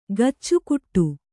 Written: ಕಚ್ಚೆಗಟ್ಟು

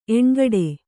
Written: ಎಣ್ಗಡೆ

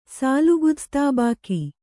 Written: ಸಾಲುಗುದಸ್ತಾಬಾಕಿ

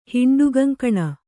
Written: ಹಿಂಡುಗಂಕಣ

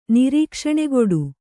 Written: ನಿರೀಕ್ಷಣೆಗೊಡು

